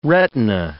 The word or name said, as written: retina